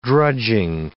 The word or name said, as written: drudging